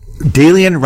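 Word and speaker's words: Daylian: But before we do that, you talk about your colleague at Yale, Dalian Kane. Am I saying the name right? – Dalian rhymes with alien.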